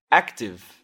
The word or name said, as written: active